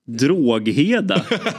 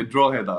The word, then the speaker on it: Drogheda: Drogheda. eller hur uttalar man det? – ja, inte riktigt så. Det är Drogheda då.